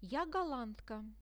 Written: ja gollandka

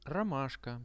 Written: romashka

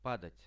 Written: padat

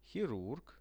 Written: hirurg

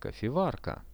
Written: kofevarka

